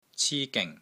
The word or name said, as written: CI1 GING3